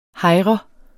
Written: hejre

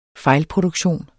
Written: fejlproduktion

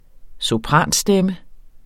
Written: SOPRANSTEMMEN

